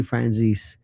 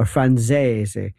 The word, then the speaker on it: Franzese: Michael Franzese, by the way, Sonny Franzese, or Franzese, if nobody ever pronounces the name correctly, but anyway, Michael Franzese, Sammy the Bull, others, they first started this thing, and now Franzese has transcended that.